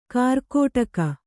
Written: ಕಾರ್ಕೋಟಕ

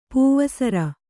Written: ಪಾವುಸೇರು

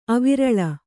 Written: ಅವಿರಲ

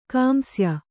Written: ಕಾಂಸ್ಯ

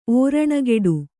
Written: ಓರಣಗೆಡು

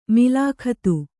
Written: ಮುಲಾಖತ್ತು

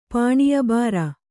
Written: ಪಾಣೀಯಬಾರ

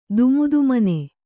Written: ದುಮುದುಮನೆ

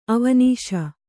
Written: ಅವನೀಶ

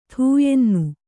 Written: ಥೂಯೆನ್ನು

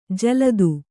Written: ಜಲ್ದು

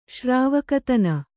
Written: ಶ್ರಾವಕತನ

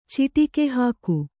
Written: ಚಿಟಿಕೆಹಾಕು

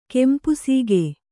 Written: ಕೆಂಪುಸೀಗೆ

